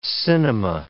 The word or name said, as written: cinema